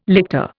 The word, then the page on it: lictor: Indian